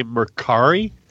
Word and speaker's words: Mercari: M-E-R-C-A-R-I. – C-A-R-I. – I'm going to say Mercari. – Mercari.